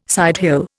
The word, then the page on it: sidehill: Indian